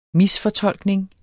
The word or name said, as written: misfortolkning